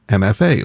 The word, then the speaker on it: Emefe: They are called MFA, and that's spelled E-M-E-F-E, but said MFA, like Masters of Fine Arts.